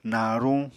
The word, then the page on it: naruñ: Apatani